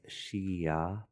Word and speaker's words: Xia: Yeah, so any letter that has, any word that has X in it, it's always pronounced like S-H. – Mm-hmm. – And then I like to think it's Shia, but it's like She-Ra, but without the R. Shia.